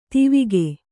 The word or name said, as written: ಧಾವಕ